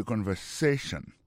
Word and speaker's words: conversation: Mot conversation, accent tombé sur avant-dernier syllabe là. You know? Listen to the conversation.